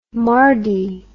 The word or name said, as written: mardie